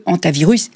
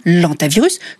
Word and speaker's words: hantavirus: tout simplement parce que le H qui se trouve à l'initiale du mot est un H aspiré. Un H aspiré qui, à la différence du H muet, ne permet ni l'élision ni la liaison. On dit donc le hantavirus et non l'antavirus, comme on dit le hibou et non l'hibou.